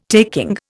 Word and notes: taking: Indian

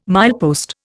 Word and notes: milepost: Indian

